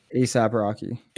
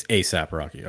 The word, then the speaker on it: ASAP Rocky: but is it ASAP Rocky? – ASAP Rocky. – It's ASAP Rocky.